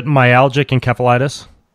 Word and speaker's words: myalgic encephalitis: That's how you pronounce it, myalgic encephalitis? – Yes, or myalgic encephalomyelitis.